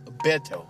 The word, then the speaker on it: Beto: Is it Beto? – Beto. B-E-T-O.